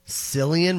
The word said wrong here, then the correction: Cillian: Murphy? – Killian, actually. – Oh, yeah. Is it Killian Murphy? – It's Killian Murphy.